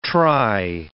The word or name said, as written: try